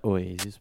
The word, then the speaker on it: Oasis: No, dai, a parte gli scherzi, questi erano i Oasis, gli Oasis, eh, giusto? Si pronuncia Oasis.